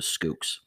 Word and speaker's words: Schoox: And Schoox is spelled S C H O O X. That's S C H O O X. So they pronounce it Skooks.